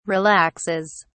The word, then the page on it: relaxes: British English